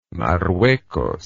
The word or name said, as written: marruecos